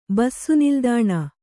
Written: ಬಸ್ಸುನಿಲ್ದಾಣ

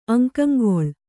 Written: ಅಂಗಂಗೊಳ್

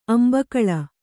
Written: ಅಂಬೇಗಾಲು

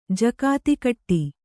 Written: ಜಕಾತಿಕಟ್ಟಿ

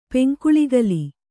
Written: ಪೆಂಕುಳಿಗಲಿ

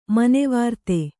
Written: ಮನೋವಿರತಿ

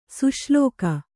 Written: ಸುಶ್ಲೋಕ

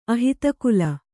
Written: ಅಹಿತಕುಲ

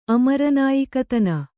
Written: ಅಮರನಾಯಿಕತನ

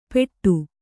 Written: ಪೆಟ್ಟು

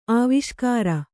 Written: ಆವಿಷ್ಕಾರ

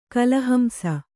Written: ಕಳಹಂಸ

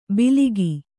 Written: ಬಿಲಿಗಿ

